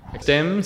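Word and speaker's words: STEMN: STEM stands for the Science, Technology, Engineering and Mathematics Network. So the N is silent.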